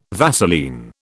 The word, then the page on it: Vaseline: British English